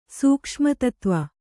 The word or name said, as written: ಸೂಕ್ಷ್ಮತತ್ವ